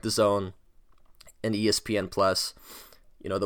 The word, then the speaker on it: DAZN: You know, back to the DAZN, and if you guys aren't really that familiar with the zone, it's spelled D-A-Z-N, all in caps. It looks like Dazin or Dazin, but it's pronounced the zone.